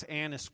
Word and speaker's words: Annas: Now, it's really important that you pronounce, y'all forgive me, make sure you pronounce Annas correctly, all right? It is with a short vowel, not the long vowel, okay?